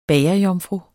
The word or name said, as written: bagerjomfru